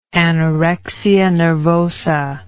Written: anorexia nervosa

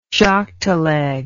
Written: jockteleg

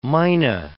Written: mynah

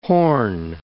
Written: Hoorn Islands

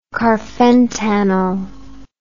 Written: carfentanil

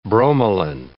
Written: bromelin

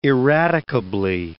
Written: irradicably

